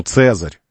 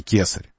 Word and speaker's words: цезарь: «К» не было у них, «ц» было. Поэтому «digitus impodicus». Ну, не было у них произношения на «к», «к», «к», было «ц». Поэтому «цезарь», а не «кесарь», «цезарь».